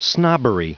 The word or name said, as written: snobbery